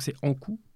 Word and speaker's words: Ankou: Donc ça s'écrit A-N-K-O-U. Donc c'est Ankou. Et on dit l'Ankou. Donc L'apostrophe Ankou.